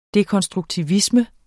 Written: dekonstruktivisme

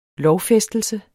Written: lovfæstelse

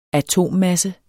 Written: atommasse

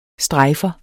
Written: strejfer

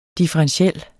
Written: differentiel

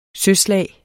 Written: søslag

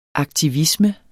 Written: aktivisme

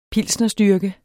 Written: pilsnerstyrke

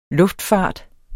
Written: luftfart

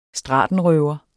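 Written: STRATENRØVER

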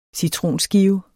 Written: citronskive

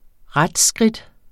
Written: retsskridt

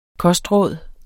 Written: kostråd

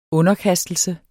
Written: underkastelse

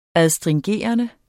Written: astringerende

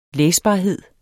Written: læsbarhed

